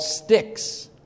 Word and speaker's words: stichs: So Hebrew poetry was divided into lines, and each line was broken into two or more parts called sticks. S-T-I-C-H-S, pronounced stick, like a stick, you know, breaking a stick.